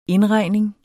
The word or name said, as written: indregning